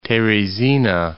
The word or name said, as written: Teresina